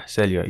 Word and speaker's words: Celio: I'm Celio. And like, so it's pronounced Celio. – Yeah, Celio. It's French.